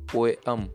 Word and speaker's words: poem: poem not poem V D O, not V D There is a slight difference in this pronunciation VDO, not VDO.